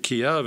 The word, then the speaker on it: Kyiv: I've always pronounced it Kiev, and many of the diplomats, the people who work in the country, pronounced it Kyiv.